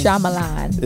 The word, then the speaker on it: Shyamalan: How do you say his name? – Shyamalan.